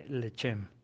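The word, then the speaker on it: lechem (לחם): ¿Sabes cómo se dice pan en hebreo? Se dice lechem.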